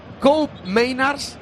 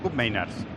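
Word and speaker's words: Koopmeiners: Por si alguien tiene dudas, como se escriben muy raros, hemos estado mirando la pronunciación antes del partido. Cup Miners, el pivote. – Cup Miners.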